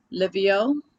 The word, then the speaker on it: Lyvio: Today we have DJ Divine and we have Lyvio.